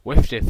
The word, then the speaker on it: WFDF: But it is WIFDIF. That's a new. Thanks, Tom, for introducing that pronunciation to us in the.